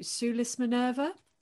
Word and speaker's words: Sulis Minerva: So the subject of your first book was the temple to, and you have to correct me if I say this wrong, to Sulis Minerva. – That is right. Yes, that's fabulous. The correct pronunciation.